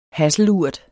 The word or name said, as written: hasselurt